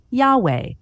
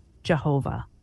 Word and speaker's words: Yahweh: The scholarly consensus is that it should be pronounced Yahweh. But since at least the 1500s, it's been translated into English with the spelling and pronunciation of Jehovah.